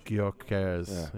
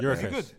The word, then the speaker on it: Gyökeres: Gyökeres. Good. – I watched him at Coventry. He was good, yeah. – He was too good for that league. – Soft G. – Gyökeres, yeah.